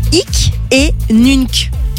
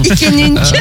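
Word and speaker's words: hic et nunc: C'est vrai, mais du coup, ils ont créé une application qui est top, qui s'appelle Ick et Nunc. Ick, ça s'écrit H-I-C, et Nunc, N-U-N-C, c'est du latin. – Il y aurait plus un truc de rencontre, mais bon. – Ick et Nunc.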